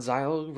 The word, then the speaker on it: Xyle: You know what Xyle rhymes with? – Kyle.